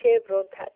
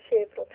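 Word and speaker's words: Chevron: شرکت بعدی سوکال که استاندارد اول کالیفرنیا است که این کشور این شرکت تحت نام شبرون بعد از نام تجاریش میشه، درست میگم تلفظ میکنم؟ – بله بله. – از آلمانیش هم میگن شبرون،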